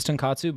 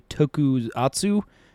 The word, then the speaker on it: tokusatsu: Hey, speaking of which, the word that I thought was tenkatsu, but is actually tokusatsu.